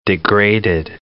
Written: degraded

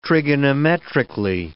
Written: trigonometrically

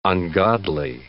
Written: ungodly

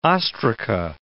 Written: ostraca

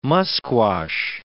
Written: musquash

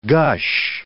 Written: gush

